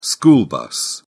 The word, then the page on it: school bus: British English